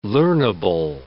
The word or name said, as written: learnable